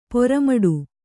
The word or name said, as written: ಪೆರ್ಮಡಿ